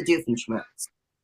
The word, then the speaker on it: Doofenshmirtz: Dr. Doofenshmirtz.